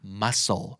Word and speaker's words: muscle: muscle สะกดว่า muscle นะครับแต่ว่าอ่านว่า muscle